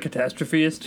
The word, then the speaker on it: Catastrophist: Strictly because I'm overthinking it, I won't be able to pronounce it, but Catastrophist. Oh, I said it right.